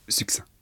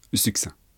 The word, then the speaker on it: succinct: Succinct. Succinct. On ne prononce pas le C.